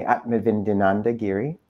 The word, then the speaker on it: Atmavidyananda Giri: Today, we're blessed to be joined by Swami Atmavidyananda Giri, and I've been assured I am saying his name correctly.